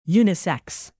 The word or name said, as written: unisex